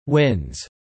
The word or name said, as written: wins